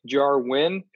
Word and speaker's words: Jarwin: Blake Jarwin, the Dallas Cowboys open emphasis on the win. Am I right? – That's why I did that part.